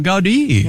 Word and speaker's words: Gaudí: Gaudí.